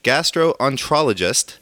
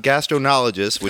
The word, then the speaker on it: gastroenterologist: It's pronounced gastroenterologist, not gastronologist, which sounds like you're going to space.